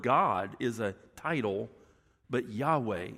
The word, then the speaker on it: Yahweh: And it just means, it's pronounced Yahweh, and it just means I am.